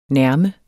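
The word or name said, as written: NÆRME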